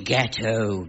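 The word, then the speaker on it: gâteaux: Oh, you mean ghetto, ghetto. It may look as if it should be pronounced ox, but in fact, one says oh.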